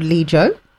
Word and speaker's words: Lijo: Right, so mine is a top for a brand called Lijo.